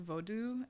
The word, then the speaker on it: vodou: But like voodoo, which is pronounced like vodou, actually.